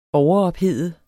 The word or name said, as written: overophede